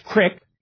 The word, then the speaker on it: creek: And there is a distinctive pronunciation of the words creek and greasy, where creek tends to be pronounced crick, and greasy tends to be pronounced with what we call a voiced rather than an unvoiced sibilant.